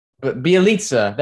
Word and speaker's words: Bjelica: Like Steph, Wiggins, Jordan Poole, Damian Lee, Draymond, Bielica, however you say his name. I'm sorry. – Nemanja Bjelica. – Bjelica, that's how you say it, is a good pickup.